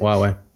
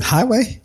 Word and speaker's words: Huawei: Huawei. You pronounce it? – Huawei. – Thank you. – Come on, man. Highway? – I didn't say highway. But Huawei.